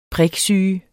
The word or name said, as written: PRIKSYGE